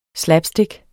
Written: SLAPSTICK

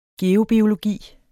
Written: geobiologi

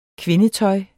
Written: kvindetøj

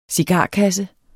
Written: cigarkasse

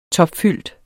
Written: topfyldt

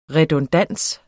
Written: redundans

